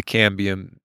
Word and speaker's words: cambium: A cadmium? – The cambium? – Cambium, I believe. – Yeah. – I'm terrible at pronouncing words, but I think cambium is the correct pronunciation.